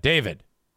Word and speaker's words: D4VD: David.